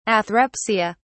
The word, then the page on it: athrepsia: British English